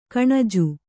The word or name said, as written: ಕೊಞ್ಞೆ